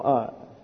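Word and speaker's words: katastrōnnumi: Katastronomy. Strone. See, right in the middle of it. Strone. Well, that has nothing to do with anything other than it's pronounced.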